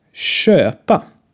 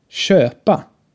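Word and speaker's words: köpa: Erstmal ein langes, geschlossenes Ö. Schöpa ist ein Beispielwort dafür. Spricht mal nach. Schöpa. Schöpa. Es entspricht etwa dem Ö äh, in Söhne, aber nicht ganz. Halt ein bisschen offener im Schwedischen aussprechen.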